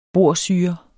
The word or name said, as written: borsyre